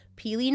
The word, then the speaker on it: pili: If you haven't had them, it's spelled P-I-L-I, peely nuts. Otherwise, you might be thinking like P-E-E-L-Y or something. No, P-I-L-I.